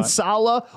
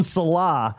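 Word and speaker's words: Saleh: pronunciation, yeah, Sala or Salah. That's right. He used to call him Salah, and it's Sala.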